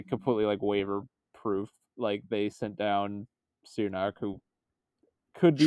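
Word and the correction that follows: Černák: Charnick is how you say his name apparently.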